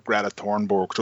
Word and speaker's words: Greta Thunberg: For anybody who's listening, you are talking about Greta Thunberg. Just you're pronouncing her name correctly. – Yeah. Sorry, I pronounced her name the Swedish way.